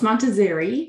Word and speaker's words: Montazeri: Close, Montazzari.